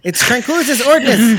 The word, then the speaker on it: Tranquilitas Ordinis: Okay, Thomas says Ordinus air bubbles. Incorrect. It's Tranquillitas Ordinus. Say it right.